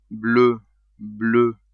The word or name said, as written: bleu-bleue